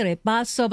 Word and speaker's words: Ripasso: It is the Lonardi Ripasso Valpolicella.